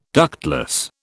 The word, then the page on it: ductless: British English